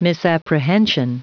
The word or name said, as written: misapprehension